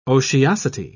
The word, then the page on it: otiosity: British English